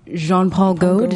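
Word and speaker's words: Jean-Paul Goude: This photo is actually, the photographer for it was Jean-Paul Gaud. Did I say it right? – Oui, mon ami.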